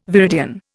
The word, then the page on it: viridian: Indian